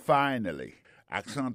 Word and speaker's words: finally: Finally, c'est F-I-N-A-L-L-Y, finally. T'as des prononciations, oui? Finally. Accent tombé sur premier syllabe là. Finally. F-I-N-A-L-L-Y, ça veut dire en bon créole, enfin, séraphin, pied fin, manger à réussir, arriver sous table là.